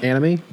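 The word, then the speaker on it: AnneAmie: So it's Anne, A-N-N-E, and then Amy is A-M-I-E. So. – Oh, in my head it was spelled like anime. – Mm-hmm. And you would think it would be Anne Amy, but I've only heard it pronounced anime.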